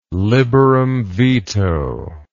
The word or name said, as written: liberum veto